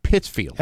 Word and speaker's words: Pittsfield: I believe it's pronounced Pittsfield. – Have you ever been there? – I have. – I don't think you know how to pronounce it properly.